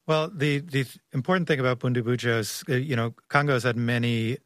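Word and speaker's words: Bundibugyo: To Shashank's point about this outbreak is caused by a rare, very deadly strain of the Ebola virus called Butembunjo. Did I say that correctly? I got it.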